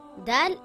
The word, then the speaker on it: Dal: Dal is a light letter and should not be pronounced heavy. Dal makes a similar sound in English to the letter D in the word dad or the word dark.